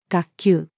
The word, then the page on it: 学究: female